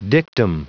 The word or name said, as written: dictum